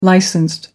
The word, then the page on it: licensed: American English